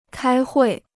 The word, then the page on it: 开会: kāi huì